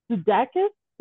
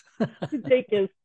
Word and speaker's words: DeDakis: Today, Houston Sleuth, I have with me John DeDakis. Did I pronounce that right? – DeDakis. – DeDakis. Oh, I'm so sorry.